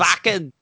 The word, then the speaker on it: Wacken: Uh it's spelled Wacken like it's in Germany so um it's Wacken.